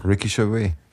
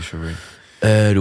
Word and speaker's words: Gervais: Ricky Gervais. – Gervais, nicht ja. Wie Gervais Obstgarten. – Ja, Gervais. Ricky Gervais.